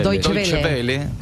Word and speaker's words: Deutsche Welle: El canal DW, ¿cómo se dice? – Deutsche Welle. – Deutsche Welle, sí.